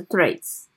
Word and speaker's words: Threads: 它不是发音念翠啦，是 threads，就是 IG 的另一个文字版的软体，嗯。